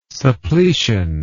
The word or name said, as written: suppletion